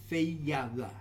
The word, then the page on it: FeyaWa: new form of Feava